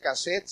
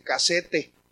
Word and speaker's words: casete: no se dice cassette, se dice casete. Así decía él, decía casete. Entonces decía yo,